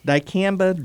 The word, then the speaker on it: dicamba: Is it dicamba drift? – Dicamba drift.